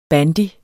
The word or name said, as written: BANDY